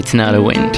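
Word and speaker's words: Tinariwen: Am I saying it right? – Yes, exactly. Tinariwin.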